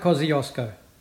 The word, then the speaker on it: Kosciuszko: Mount Kosciuszko.